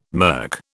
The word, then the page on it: mirk: British English